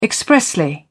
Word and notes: expressly: British English

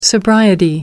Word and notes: sobriety: American English

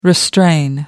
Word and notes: restrain: American English

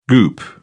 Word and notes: goop: British English

